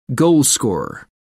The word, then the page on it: goalscorer: American English